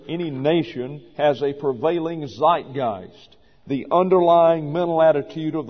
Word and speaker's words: Zeitgeist: It is an English word taken from the German, pronounced Zeitgeist, Zeitgeist.